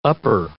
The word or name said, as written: upper